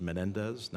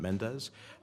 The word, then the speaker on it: Menendez: My name is Menendez, not Mendez.